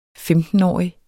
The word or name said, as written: femtenårig